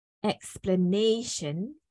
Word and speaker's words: explanation: So explanation, nay is the first stress, and then the secondary stress is the first syllable here.